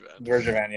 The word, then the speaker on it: Bergevin: I can never honestly pronounce his name. So like Bergervan. – Yeah. See. Yeah. You're definitely Canadian.